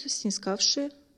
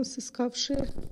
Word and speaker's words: снискавшие: Работают снискавшие. Я правильно сказала? – Все правильно сказала. – Блин, а я думала, сыскавшие.